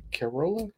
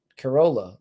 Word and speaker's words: Corolla: How do you say her name? Carola? Carola? – I when I first heard it, I thought he was saying Caroline, but it's not. It's Corolla. – Like Corolla.